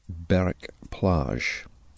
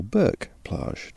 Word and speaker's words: Berck-Plage: Just outside a village called Verton, which is to the east of Berwick Plage, or Berck-plage, depends how you want to pronounce it, B-E-R-C-K.